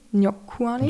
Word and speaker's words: Njokuani: Gregory äh, Rodriguez, alias Robocop, gegen Chidi Nyokuani, Njokuani. Njokuani. Ich glaube, sie haben es so ausgesprochen.